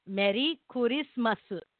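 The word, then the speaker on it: Meri Kurismasu (メリークリスマス): So, you know, R-E-R-I-B-E-E-T-A. Irabita. Because that's how they say it, right? So in Japanese, Merry Christmas is Meri Kurismasu. Merry Christmas. So that's how you say Merry Christmas.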